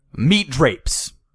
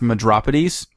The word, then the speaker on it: Medropides: He's going through and he's like, meat drapes. Meat drapes, where are you? Sir, it's pronounced Medropides. Looks like meat drapes to me.